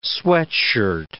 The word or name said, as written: sweatshirt